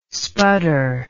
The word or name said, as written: spudder